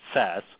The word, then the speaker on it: Sasse: Ben Sasse, I know his name ends with an E, but it's pronounced Sasse.